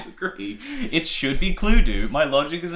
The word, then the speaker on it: Cluedo: I think it should be pronounced Cluedo. Anyway, it's Cluedo. – What? – In America, it's just Clue. And I'm like, why do we say do if it's D-O? Usually that's do. And then it would rhyme.